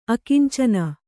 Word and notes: ಆಕುಂಚನ: ākuñcana

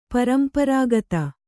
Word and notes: ಪರಂಪರಾಗತ: paramparāgata; Kannada

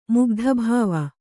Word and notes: ಮುಗ್ಧಭಾವ: Kannada

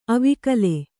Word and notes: ಅವಿಕಲ: avikala; Kannada

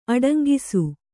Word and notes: ಅಡಂಗಿಸು: aḍaŋgisu

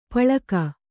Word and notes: ಪಳಕ: Kannada

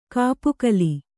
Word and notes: ಕಪ್ಪೆಗೋಲು: kappegōlu

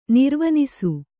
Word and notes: ನಿರ್ವಿನಿಸು: nirvinisu

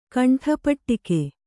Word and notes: ಕಂಠಪಟ್ಟಿಕೆ: kannada

